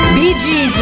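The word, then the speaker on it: Bee Gees: BG's è praticamente l'acronimo di Brothers Gibb, fratelli Gibb, quindi formato dalle iniziali B e G pronunciate in inglese, tutto qua.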